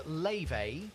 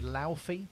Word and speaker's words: Laufey: So she is Leve. That's how you pronounce it. Looks like it should be Laufey, but it's Leve, and that's called Mad Woman.